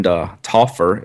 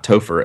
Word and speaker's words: Toffer: like our friend Toffer, it's not Topher, as he vined me the other day on on Twitter how to pronounce his name.